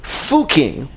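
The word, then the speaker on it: Fucking: And it's pretty well known because its name is Fuking, which is pronounced that way, but spelled like a word in English that's pronounced not quite that way, if you get my drift.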